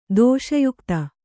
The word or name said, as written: ದೋಷಯುಕ್ತ